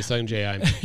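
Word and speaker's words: Sungjae Im: Depending upon your pronunciation there. But it's obviously Sungjae I'm.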